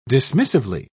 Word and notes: dismissively: British English

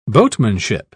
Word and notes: boatmanship: British English

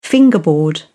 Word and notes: fingerboard: British English

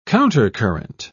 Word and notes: countercurrent: British English

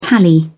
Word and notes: tally: British English

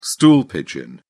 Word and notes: stool pigeon: British English

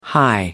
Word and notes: high: American English

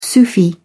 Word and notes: sufi: British English

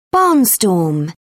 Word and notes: barnstorm: British English

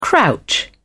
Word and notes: crouch: British English

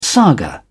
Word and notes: saga: British English

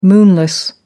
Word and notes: moonless: American English

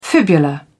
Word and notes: fibula: British English

